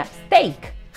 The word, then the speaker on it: stake: stacked se pronuncia Stack ¿no? Y stake se pronuncia steak, con el debido respeto, claro. Es decir, no son la misma pronunciación y otra carita que guiña.